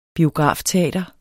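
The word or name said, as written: biografteater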